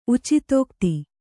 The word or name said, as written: ಉಚಿತೋಕ್ತಿ